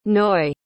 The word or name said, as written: noy